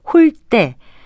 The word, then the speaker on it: 홀대: 이 경우에는 홀대, 된소리로 발음하는 것이 맞습니다. 여기서 쓰이는 한자 대접할 대자는 우대나 환대와 같이 앞 음절이 모음으로 끝나거나 니은 받침으로 끝날 때는 글자처럼 예사 소리인 대로 발음하지만 접대나 홀대와 같이 니은 받침 이외의 받침이 올 때는 된소리인 때로 발음합니다. 홀대는 푸대접이란 표현으로 순화해서 쓰기도 합니다.